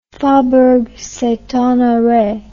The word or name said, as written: faubourg st honore